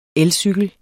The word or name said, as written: elcykel